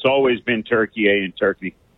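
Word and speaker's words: Türkiye: The Turks have always called it Turkey A, but in English, the pronunciation was like the bird.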